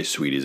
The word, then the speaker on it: Sweetees: And we should probably, it's spelled S-W-E-E-T-E-E-S. – Two T's. – Two T's. – Sweet Teas. – But when you say Sweeties, it doesn't sound like it's spelled. – No. – So you got to tell people.